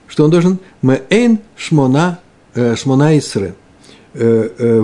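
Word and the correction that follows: шмоне эсре (שמונה עשרה): вроде «шмоне, шмоне эсре», «шмоне эсре».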